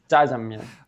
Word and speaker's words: Jajangmyeon: Jajangmyeon.